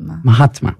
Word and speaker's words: Mahatma: Mahatma.